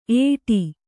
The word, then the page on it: ಏಟಿ: Kannada